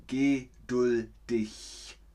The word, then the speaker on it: geduldig: meine Familie ist geduldig mit mir. So sagt man das, so spricht man das aus. Ich weiß, man schreibt es mit G, aber man spricht es aus.